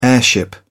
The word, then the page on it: airship: British English